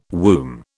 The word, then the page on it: womb: British English